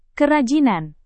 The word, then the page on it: kerajinan: indonesian